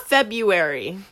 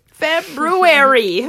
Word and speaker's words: February: If you do not pronounce the first R in February, you need to go back to school. – And if you didn't, we understand. But say it. – It's not February. How do you spell it? There is a two R's.